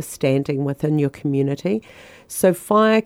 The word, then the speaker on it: whaea: So the the word fire is um, spelled W H A E A, but it's pronounced fire.